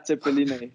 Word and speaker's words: Zeppeline: Zeppeline oder Tiroler Knödel? Habe ich das richtig ausgesprochen überhaupt? – Ja, Zeppeline.